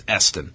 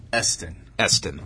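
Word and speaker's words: Iestyn: I hope I'm pronouncing that accurately. It was spelled I-E-S-T-Y-N. I believe it's Eston. – Eston.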